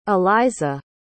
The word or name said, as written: alizah